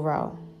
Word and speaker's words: Reaux: It's called Devil Row, but it's spelled like Row, R-E-A-U-X, like Creole, how we spell it in our culture.